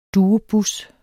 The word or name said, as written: duobus